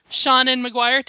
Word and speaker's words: Seanan McGuire: But we're super, super stoked to be joined by very special guest, Seanan McGuire.